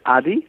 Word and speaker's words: Adi: No, you said it right. It's Rohit Adi. That's how I pronounce it.